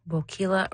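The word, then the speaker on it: Boquila: Bokeela rhymes with tequila.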